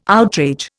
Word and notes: outrage: Indian

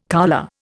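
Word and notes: coulée: Indian

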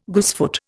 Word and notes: goosefoot: Indian

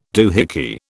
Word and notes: doohickey: British English

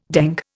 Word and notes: dengue: Indian

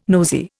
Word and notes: nosey: Indian

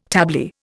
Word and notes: tablier: Indian

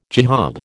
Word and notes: jihad: American English

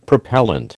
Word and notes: propellant: American English

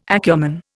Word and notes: acumen: Indian

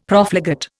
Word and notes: Profligate: Indian

